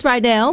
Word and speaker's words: Rydell: It's not Riddle, it's Rydell, like the high school, so I want to make sure I said that correctly.